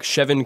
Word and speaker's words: Chevin: Let's start with the quarterback, Chevin Cordero. It's an interestingly pronounced name. It looks like it would be pronounced like Chavon, something like that. It's Chevin Cordero,